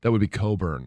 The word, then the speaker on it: Cockburn: It's spelled, it's not C-O, what, how would you spell Coburn? – C-O-B-U-R-N. – C-O-E, yeah. Yeah, no, it's a C-O-C-K. – Yes. – Yes, which is not normally pronounced as Co.